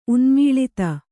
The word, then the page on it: ಉನ್ಮೂಳಿತ: Kannada